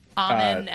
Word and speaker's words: Amen: So it's how do you pronounce the two Thompsons' first names? – Amen and Osser.